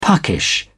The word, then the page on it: puckish: British English